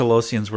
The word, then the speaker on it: Talosian: I'm glad you got that one, because I wasn't sure how to pronounce is it Talassian? – Anyway. He said Talosian.